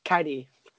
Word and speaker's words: Kairi: Yeah, I think it's funny the way you say Kairi. You say it like it's Kyrie Irving or something. It's just Kairi.